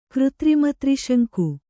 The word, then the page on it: ಕೃತ್ರಿಮತ್ರಿಶಂಕು: křtrima triśaŋku